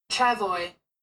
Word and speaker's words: travois: Oh, that's Travoy. How you pronounce that. – Huh? Aren't you embarrassed? – Travoy. – Travoy. – I've only ever read that word. I've never tried to say it.